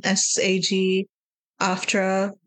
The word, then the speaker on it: SAG-AFTRA: from SAG after like SAG-AFTRA that's that's how you can pronounce SAG it, after yeah.